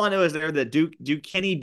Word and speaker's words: Duquesne: BYU and Duquesne.